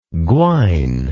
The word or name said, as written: gwine